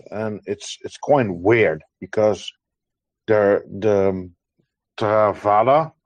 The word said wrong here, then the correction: Tavrida: I think, if I'm saying it correctly, the ta, no the Tavrida. – Yeah, yes. Thank you, uh, Doman, with your pronunciation.